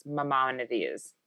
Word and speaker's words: Maimonides: I think it's Maimonides. Maimonides. Yes. Because there's still like a chain of hospitals in New York, actually, I think that's named after this person. And it's Maimonides.